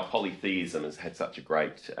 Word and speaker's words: polytheism: First thing I want to say is I've been pronouncing it wrong a lot of the time. I've been saying polytheism, and it's polytheism.